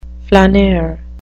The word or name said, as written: flaneur